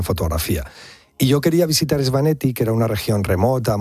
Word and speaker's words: Svaneti: Lo que más te impresionó del Gran Cáucaso, ¿no?, fue la región de Svaneti. – Así lo has pronunciado, bien, de maravilla.